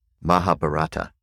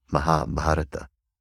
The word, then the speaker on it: Mahabharata: Mahabharata, which in the West people often say the Mahabharata, but that's a mispronunciation. It's Mahabharata.